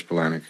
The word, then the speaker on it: Palahniuk: Je schrijft het Chuck Polanek. Ja, Palaniuk. Eh, volgens mij spreek je daar Polanek.